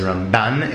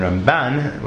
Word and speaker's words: Ramban: And not to be confused with Rambam himself, Rav Moshe ben Nachman, pronounced at least by Ashkenazim as Ramban, in contradistinction to Ashkenazim's Rambam, as opposed to Sfaradim, who pronounced both Mila Ra, in other words, with the accent on the second syllable, Rambam and Ramban, which can, for me personally, can sometimes be confusing.